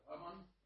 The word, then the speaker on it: Аман: Чому у нас присутній Микола Сергійович Аман? Правильно, як я наголосив? – Так, Аман.